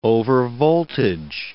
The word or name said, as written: overvoltage